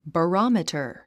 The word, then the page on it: barometer: bərάmitə r; バァロォミタァ